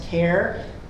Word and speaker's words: Kerr: I'm going to refer you to a cultural center called K-E-R-R. In Arizona, it's pronounced CARE, the CARE Cultural Center.